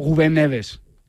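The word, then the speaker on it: Rúben Neves: Bueno, Cristiano y Vitinha y Rubén Neves y Rafa Leao, Rubén Neves.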